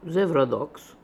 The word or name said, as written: Zevrodox